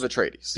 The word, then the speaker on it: Atreides: I think you're pronouncing too much of the I. – House of Atreides. – I think it's Atreides, but Atreides. – House of Atreides.